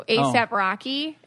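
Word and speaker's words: A$AP Rocky: I mean, I don't know if that's how you say it because it's the ASAP, but the S is a money sign. – ASAP? – So ASAP Rocky is his name.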